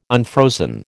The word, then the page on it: unfrozen: American English